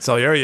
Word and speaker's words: Salieri: So this is because of this dark opera, this is where Salieri, did I say it right? – Salieri, yes.